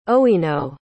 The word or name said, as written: owino